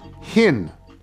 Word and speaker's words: Hinn: From Paige Hinn, because it says it rhymes with Squin, so Paige Hinn.